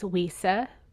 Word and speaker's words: Weesa: But yeah, so Will Smith, and then you have Stockard Channing, who plays Weesa, which is like a riff off of Louisa.